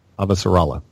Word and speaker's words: Avasarala: See, now I have to relearn how to pronounce her name because the audiobooks are different. – Yeah, the audiobook is Avasarala. The TV show is Avasarala. – Avasarala, right. Okay.